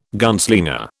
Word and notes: gunslinger: British English

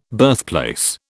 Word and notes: birthplace: British English

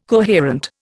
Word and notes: coherent: Indian

